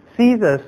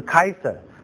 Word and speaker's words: Caesar: 该撒利亚其实不是一个简单的字眼，该撒利亚是遵从 Caesar 的，就是凡是跟该撒两个字有关的， Caesar 是我们英文的发音哈，如果是到拉丁文的就是 Kaiser， Kaiser 也是德文里面的 Kaiser，就是国王的意思哈，那也是俄国那个 Tsar 那个字哈，就是你发 S 的音或者发 K 的音。